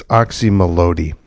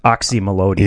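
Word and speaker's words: Oczy Mlody: I think it's Oxymelody. – You know what? We have a consensus. – Okay. – That's what Wayne Coyne called it. I heard him pronounce it. Oxymelody.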